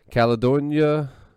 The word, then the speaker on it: Caledonia: Caledonia, Missouri.